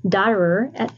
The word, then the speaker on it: Dotterer: And I'm excited to introduce you to my guest, Sherry Dotterer.